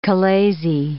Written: chalazae

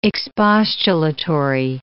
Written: expostulatory

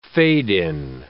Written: fade-in

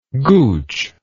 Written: gooch

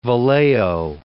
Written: Vallejo

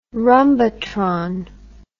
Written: rhumbatron

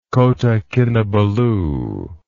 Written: Kota Kinabalu

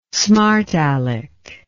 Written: smartalec

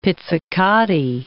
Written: pizzicati

